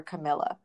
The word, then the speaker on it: Camela: It's pronounced like Pamela, except with a C, because I've been mispronouncing her name and calling her Camela.